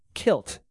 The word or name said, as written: kilt